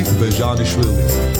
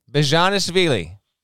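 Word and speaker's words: Bezhanishvili: It's pretty easy. It's Georgie Bajanishvili. – There we go. Bajanishvili. Bajanishvili. Say it with me. – Bajanishvili. – There you go.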